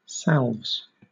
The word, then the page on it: salves: British English